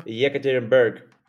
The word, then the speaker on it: Yekaterinburg: Yekaterinburg.